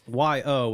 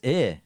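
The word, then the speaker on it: Yö: The name of the tape is Y-O with an umlaut over the O. You thought you just... that you still pronounce it yo. – Okay. – But you were wrong. – Oh, how is it? Oh, it's eh. – Yeah. – Eh. – It means like night.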